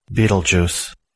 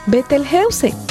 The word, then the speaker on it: Betelgeuse: Betelgeuse. – Betelgeuse. – Betelgeuse. – Betelgeuse. – Betelgeuse. – Betelgeuse. – Eran 6 pronunciaciones. Ya ves, en español puedes decirlo como lo pronunciaba el astrónomo. Betelgeuse.